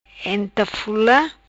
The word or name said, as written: Entafula